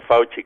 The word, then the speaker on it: Fauci: Sí, efectivamente, y todas las personas en Estados Unidos han dicho eso, Fauci, o Fauci, como se debe pronunciar, y otros destacados científicos han dicho no se va a poder tener tan pronto.